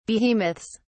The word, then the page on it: behemoths: British English